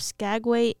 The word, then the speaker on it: Skagway: S-K-A-G-W-A-Y. – Skagway. That's how I'm pronouncing it.